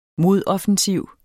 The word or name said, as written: modoffensiv